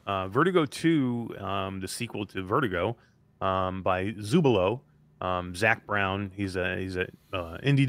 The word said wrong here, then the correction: Zlubo: developer, there's Zlubo, actually. I always pronounce that wrong. Zlubo.